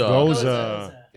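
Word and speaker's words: Gose: Is it Goose or Goza? – Goza.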